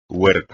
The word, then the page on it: huerta: espagnol